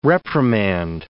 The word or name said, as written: reprimand